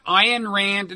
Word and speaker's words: Ayn Rand: Did not Ayn Rand, and that's another name I'm probably mispronouncing, right? – One you got right.